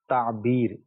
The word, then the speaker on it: تعبیر: ہاں, تلفظ میں شاید حرف آگے پیچھے ہو گیا. لفظ جو ہے تعبیر با کے ساتھ, یہ درست ہے.